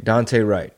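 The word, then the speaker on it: Daunte Wright: I don't know how to pronounce the gentleman's name. – Dante Wright. – There you go. Dante Wright.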